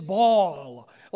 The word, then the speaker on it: Baal: The right way to pronounce it, we think, is Baal, like with a long A sound. But I'm just going to say Baal because that's the way I learned it for so long, and it's hard to break old habits.